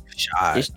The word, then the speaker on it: Shad: Is it Shad or Shot? – Shot. – Ain't it Shot? – Yeah, it's Shot. – Ain't it S-H-A-A-D? – I guess.